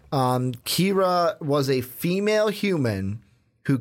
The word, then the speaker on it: Kira: Yeah, so it's Kira. I was right. Because okay. It says here it's pronounced K-I-R-A.